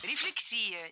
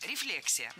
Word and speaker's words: рефлексия: Рефлексия. Неверно. В этом слове ударение должно падать на второй слог. Рефлексия. Для запоминания предлагаю однокоренное существительное с непроблемным ударением. Рефлекс. Рефлекс. Рефлексия.